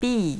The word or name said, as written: bi